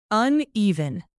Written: uneven

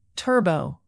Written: turbo

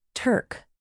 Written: turk

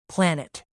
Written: planet

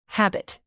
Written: habit

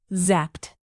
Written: zapped

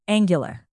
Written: angular

